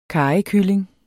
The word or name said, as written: karrykylling